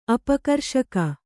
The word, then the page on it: ಅಪಕರ್ಷಕ: apakarṣaka